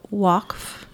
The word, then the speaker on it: Waqf: And you chose to study the Waqf. – Mm-hmm. – Did I pronounce that right? – You did.